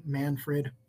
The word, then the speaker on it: Manfred: Manfred Weichsel.